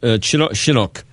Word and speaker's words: Chinook: Is that how it's pronounced? Chinook. – Yes, we call king salmon Chinook salmon here in Canada.